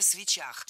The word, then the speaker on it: свечах: На свечах, неверно, правильно, на свечах. Даже если речь идет об автомобильных свечах. Особенность склонения этого слова заключается в том, что ударение всегда падает на окончание. И в единственном числе, и во множественном.